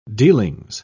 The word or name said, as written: dealings